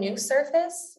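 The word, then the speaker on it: NUsurface: The implant is called New Surface, and that's spelled N-U Surface.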